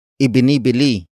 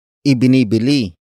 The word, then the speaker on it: ibinibili: Word for word it's ibinibili nila ang nanay ng bagong bag. Ibinibili nila ang nanay ng bagong bag.